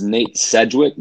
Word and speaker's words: Sedgwick: His name is Nate Sedgwick.